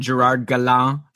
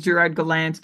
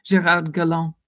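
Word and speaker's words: Gérard Gallant: And Gerard Gallant, sorry, I said it incorrectly. – Well, no, in the Anglophone media, I hear him frequently reference as Gerard Gallant. But yes, if we're all paying respect to his heritage, Gerard Gallant.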